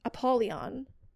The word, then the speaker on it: Apollyon: Apollyon, has wished to meet him, so he brought him there. – Just say something. – What, did I say the name wrong? – No, you didn't.